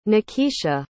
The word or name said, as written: nakeisha